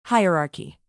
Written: hierarchy

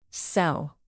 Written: cell

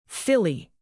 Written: filly

